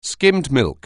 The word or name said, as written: skimmed milk